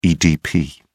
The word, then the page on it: edp: British English